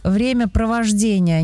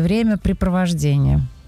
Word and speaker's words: времяпровождение: Недавно узнала, что правильно говорить «времяпровождение», а не «времяпрепровождение».